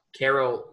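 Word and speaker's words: Karol: Jerry, pronounce that again. – It was like Carl Wojtyla, right? – Carol Wojtyla. Yeah.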